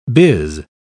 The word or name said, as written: biz